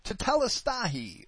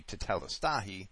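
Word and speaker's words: tetelestai (τετέλεσται): She asks, Yahshua's last word was tetelestai, or tetelestai, or teteleste, depending on how you want to pronounce the Greek. I would say tetelestai, meaning it is finished.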